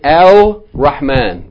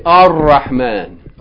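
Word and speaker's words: Ar-Rahman (الرحمن): But if the word begins with a sun letter, you're not going to pronounce the L. You're not going to say Al-Rahman. But you're going to say what? It's written, but it's not pronounced. You're going to say Ar-Rahman.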